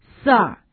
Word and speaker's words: sir: 接下来的这个单词 sir, sir, 注意它的发音。那么 IR, 在这个单词当中发的是长音 er, sir, 名词表示先生。